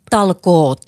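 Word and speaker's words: talkoot: And if we could encourage the rest of the world to have this sense of Finnish Talco, how do you say that? – Talkot. – Talkot. – There's a T Talkot. – In the end. – Oh, Talkot. Yeah.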